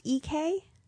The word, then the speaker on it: Iekei: It's pronounced EK. – EK. – Yeah, but it's spelled I E K E I. – Like leaky? – Yeah, but it's an I.